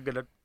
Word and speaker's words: Gadot: Gal Gadot is how Gal actually pronounces herself with a hard T on the end. Gadot.